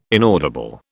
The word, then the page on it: inaudible: British English